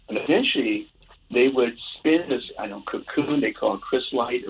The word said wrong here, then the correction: chrysalis: Chrysalis, I think, is the Thank you. Thank you. That's the right pronunciation, chrysalis.